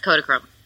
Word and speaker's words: Kodachrome: And then Kodachrome, am I saying that right? Kodachrome? – Yes, Kodachrome.